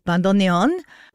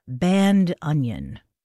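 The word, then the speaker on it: bandoneón: And a note on the pronunciation, I love this, of the word bandoneon. One of my music pals, Emmanuel Trifilio, is a professional bandoneonisto. And in order to make it easier for non-Spanish speakers to pronounce, he very graciously invites you to say bandoneon. Bandoneon. There you go.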